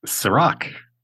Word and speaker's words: Cirak: So, Chris, and I forgot to ask you how to pronounce your last name. – Cirak. – Oh, I would have said it wrong.